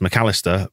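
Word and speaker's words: Mac Allister: Legacy fan Kevin as well, pointing out that people will be annoyed by Alexis McAllister for having a silly name that is apparently pronounced McAllister, but the voice in my head can't help but pause between Mac and Alistair, like I just did, McAllister.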